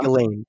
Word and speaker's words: Ghislaine: Chris, did you say Chris Lane? – Isn't that her name? Chris Lane? Chris Lane? – No, yeah. Ghislaine. – Well, it said on the documentary, it said Chris. Whatever. – No, it's Ghislaine.